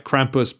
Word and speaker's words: Krampuss: It's like Krampuss, but with two S's, so like a pussycat.